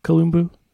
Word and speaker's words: Kalumbu: I wanted to ask you, did I pronounce your last name correctly, Kalumbu? – You pronounce it correctly, yeah.